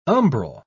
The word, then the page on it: umbral: British English